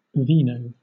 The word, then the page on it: vino: British English